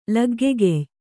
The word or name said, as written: ಲಗ್ಗೆಗೆಯ್